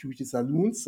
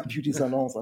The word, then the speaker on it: Beauty Salons: nicht Beauty Saloons, sondern Beauty Salons. Also Beauty Salons.